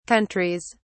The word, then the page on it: countries: British English